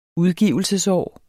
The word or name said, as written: udgivelsesår